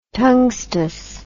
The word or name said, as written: tungstous